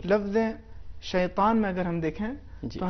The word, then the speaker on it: شیطان: تو اس کا صحیح تلفظ اس طریقے سے ہے شیطان اعوذ باللہ من الشیطان الرجیم. اس میں یہ موٹے انداز سے ادا ہوگا.